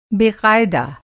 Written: बेक़ायदा

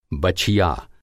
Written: बछिया

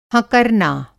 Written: हँकरना

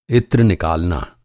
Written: इत्र

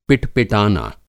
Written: पिट-पिटाना

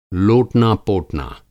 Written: लोटना पोटना

